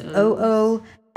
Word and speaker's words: OOFOS: I may not be saying that wrong, but I think it's UFOs. It's OOFOS.